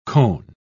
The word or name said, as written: cone